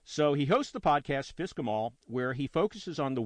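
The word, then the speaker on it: FSCK-em all: He hosts the podcast, which is pronounced Fisk 'em All. I have a link, okay? It's spelled F-S-C-K hyphen E-M space all.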